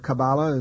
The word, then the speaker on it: Kabbalah: And the Kwisatz Haderach in the Kabbalah, or Kabbalah as it's pronounced, is a person who can be many places at once, who has this capability.